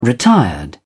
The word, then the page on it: retired: British English